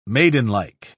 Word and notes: maidenlike: British English

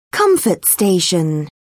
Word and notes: comfort station: British English

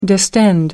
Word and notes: distend: American English

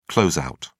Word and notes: closeout: British English

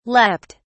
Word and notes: leaped: British English

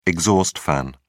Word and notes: exhaust fan: British English

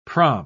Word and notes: praam: British English

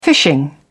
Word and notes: fishing: British English

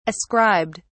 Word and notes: ascribed: British English

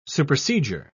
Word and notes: supersedure: British English

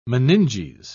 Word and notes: meninges: British English

